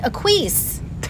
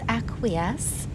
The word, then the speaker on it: acquiesce: And I pronounced it wrong. I said, acquies. And I had one girl raise her hand and go, it's acquiesce.